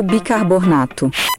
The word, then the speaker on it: bicarbonato: Você precisa prestar atenção à forma como esta palavra é escrita. E você vai pronunciar da mesma forma como ela é escrita. Então, o correto é bicarbonato. Só tem um Rzinho aí, bicarbonato.